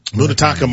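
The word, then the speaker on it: Munetaka: Mutataka Murakami. – That was really good. – Thank you. – Say that, not you, oh. – Myron. Say that name again. – Mutataka Murakami.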